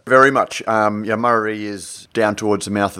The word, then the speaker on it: Murarrie: Murarrie, is that how you pronounce it? – Murarrie. – Murarrie, yeah, God, I knew I'd get it wrong, in, you know, Brisbane's eastern suburbs.